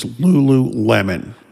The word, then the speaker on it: Lululemon: I went and asked a young person I know, how do you pronounce the name of this company? And she did let me know it is Lululemon.